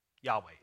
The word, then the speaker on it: Yahweh: If we take the consonants that we have of that, YHWH, scholars, they will disagree, but the majority of them will say you pronounce it Yahweh.